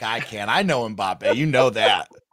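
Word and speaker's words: Mbappé: By the way, as an aside, congratulations on the correct pronunciation of Kylian Mbappe.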